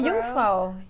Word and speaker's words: Jungfrau: Oh, Jungfrau.